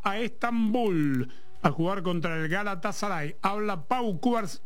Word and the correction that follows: Cubarsí: ¿Cómo es que se pronuncia? – Pau Cubarsí. – Yes. Pau Cubarsí.